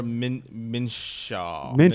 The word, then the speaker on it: Minshew: It's Gardner Minshew.